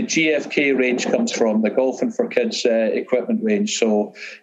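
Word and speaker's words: Golphin: And so just for the sake of the listeners, when you say Golfin, it's not G-O-L-F-I-N-G. Can you spell it for the customer or for the listeners? – So it's G-O-L-P-H-I-N.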